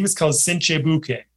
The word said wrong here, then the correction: buchi: Buchi. Yep. – Cinque. And buchi. So let's see, how to pronounce buchi.